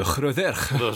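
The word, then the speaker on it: Rhydderch: C'est un ensemble de textes qui se trouvent dans deux livres, le livre rouge d'Ergest et le livre blanc de Riderch, qui sont... – Je crois que tu veux dire le livre blanc de Hroderch. – Si on va aller très dans la prononciation galloise.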